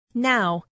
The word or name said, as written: now